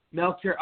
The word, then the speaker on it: Melchior: Is it Melchior? Did I do it right? – It's Melchior. – Melchior. Okay. All right. I was close.